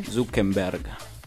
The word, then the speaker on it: Zuckerberg: Il colore è bianco e blu, proprio come il portale di Mark Zuckerberg, detto bene? – Zuckerberg. – Va bene.